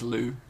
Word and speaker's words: Liu: I think it's Liu. – Liu? – Sima Liu. – Sima Liu.